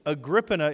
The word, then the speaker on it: Agrippina: Agrippina is the right way, correct, history teacher? It is.